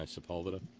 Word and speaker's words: Sepulveda: I said that right, didn't I? Sepulveda? – Yeah.